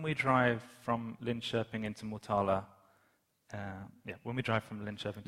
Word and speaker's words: Motala: Och då ser man att en stor skylt där står Motala, Östergötlands huvudstad.